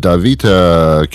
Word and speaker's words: DaVita: Javier Rodriguez is president and CEO of DaVita Kidney Care. Did I pronounce that properly? DaVita? – Yes, you did.